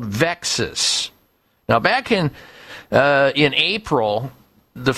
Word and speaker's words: VEXAS: VEXAS, V-E-X-A-S, sort of rhymes with Texas, VEXAS syndrome.